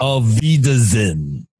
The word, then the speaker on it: Auf Wiedersehen: Auf Wiedersehen? – Auf Wiedersehen. – Auf Wiedersehen. – Oh, there you go. – How do you say it? – Auf Wiedersehen. – So you say auf? – Auf? – Parang dog.